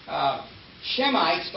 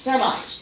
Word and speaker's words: Semites: Shemites, but we don't pronounce the H in Hebrew, it's Semites.